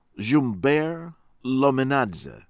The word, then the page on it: Dzhumber Lominadze: American English